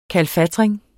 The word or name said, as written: kalfatring